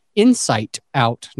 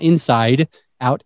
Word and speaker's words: Insight Out: I didn't say that wrong. Insight Out, not Inside Out, Insight.